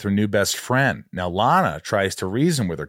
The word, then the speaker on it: Lana: Lana rhymes with banana. Emily turns him away, saying that Lana already took off.